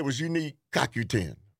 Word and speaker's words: CoQ10: CoQ10.